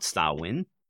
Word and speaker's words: Samhain: In fact, the festival of Samhain comes from there, although it's spelled Samhain, but it's pronounced Samhain.